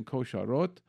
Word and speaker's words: Kosharot: I'm saying it in the Hebrew pronunciation, Kosharot, and they are in Israel.